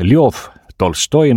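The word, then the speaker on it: Ljov Tolstoi (Лев Толстой): Õnnitlen kõiki, kes selles helilõigus suutsid ära tunda Lev Tolstoi ehk Ljov Tolstoi nagu suurmees ise oma eesnime hääldas.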